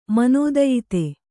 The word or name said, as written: ಮನೋದಯಿತೆ